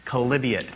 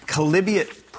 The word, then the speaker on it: Chalybeate: Chalybeate. Chalybeate pools.